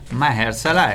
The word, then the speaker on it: Mahershala: Mahershala, ecco, questa è la pronuncia corretta, quindi se l'avete, se l'avete pronunciato diversamente fino ad oggi, cambiate.